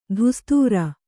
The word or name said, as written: ಧುಸ್ತುರ